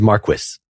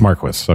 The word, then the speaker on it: Marquis: Just an FYI, I specifically pulled up a video just so I could see how to pronounce his name, and it is Marquis. It's Marquis. Okay.